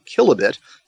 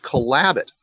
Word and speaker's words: Kelabit: It's spelled kilobit. It's pronounced Kalabit.